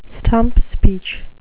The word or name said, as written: stump speech